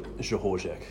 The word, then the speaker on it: Rzechorzek: CS, Conrad Stewart, Rzechorzek.